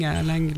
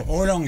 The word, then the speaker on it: Haaland: Haaland, alors, parce que ça... – Je sais pas comment en fait, le A-A se prononce O, en danois, c'est comme ça.